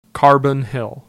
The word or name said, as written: Carbon Hill